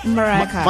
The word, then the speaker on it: Mariah Carey: Eso, ahorita viene Mariah Carey, ¿lo dije bien? – Mariah Carey.